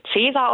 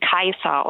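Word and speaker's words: Caesar: Der Name Cäsar würden viele Leute Cäsar aussprechen. Ich würde ihn Kaiser aussprechen. Warum? Also das C wie ein K und das AE wie ein Ei.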